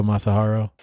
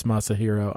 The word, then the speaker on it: Masahiro: Way to go, Masahiro. Oh, I I totally mispronounced that. That's Masahiro. I'm sorry.